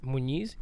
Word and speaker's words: Muñiz: All right, so my name is Raul Muñiz. Did I say my last? Muñiz? Yes, I said it right.